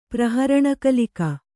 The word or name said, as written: ಪ್ರಹರಣಕಲಿಕ